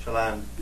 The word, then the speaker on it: Chaland: Yep, Chaland. Is that how it's pronounced? – Yep. – Chaland.